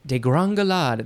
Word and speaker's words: degringolade: Degrongelade.